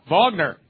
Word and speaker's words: Wagner: Oh, that's how they say it? Oh, they say it with a V. Sorry. Okay. Wagner.